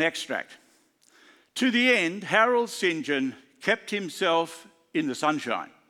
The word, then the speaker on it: St. John: It's spelt St. John, but he didn't like to be called St. John. He said, I'm not a very saintly person, although most who knew him thought he was. So he preferred to pronounce it St. John, Harold St. John.